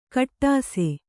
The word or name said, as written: ಕಟ್ಟಾಸೆ